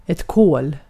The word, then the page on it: kol: /koːl/